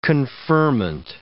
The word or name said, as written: conferment